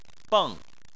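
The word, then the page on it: beng: tone 4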